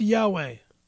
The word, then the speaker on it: Yahweh: Pronounce God's name correctly. It's Jehovah. Or if you're Hebrew, it's Yahweh.